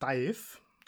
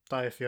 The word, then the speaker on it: DIIV: Den kennt man vielleicht als Frottmann der Band Dive. Also D-I-I-V. – So werden die ausgesprochen. – Dive, ja.